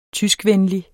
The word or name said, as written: tyskvenlig